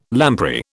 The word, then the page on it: lamprey: British English